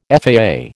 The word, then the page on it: FAA: American English